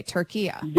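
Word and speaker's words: Türkiye: Okay, Turkiya. – There you go. That's perfect. That's how it's pronounced. It's always been pronounced that in Arabic language. – Okay. – It's a, you know, a short A at the end, not the long A.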